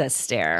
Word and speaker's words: Esther: It is Esther. – Esther. – Okay, so... – As in Fred Astaire. – As in Fred Astaire, but it's spelled like Esther.